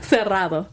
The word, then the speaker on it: cerrado: Cerrado. Did I say that right? – Cerrado. – Cerrado. – Yes.